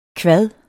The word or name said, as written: kvad